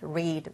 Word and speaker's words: read: 그래서 대표적으로 become, became, meet, met, read, read. read 같은 경우는 스펠링 같지만 발음이 다르죠? read라고 읽어야 돼요.